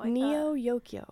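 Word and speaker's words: Neo Yokio: Neo-Yokio. – Yeah, like Neo-Tokyo, but yeah. Neo-Yokio. – And Neo, yeah. New, Yokio. – Except spelled with an I in the Kyo.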